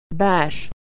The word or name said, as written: bash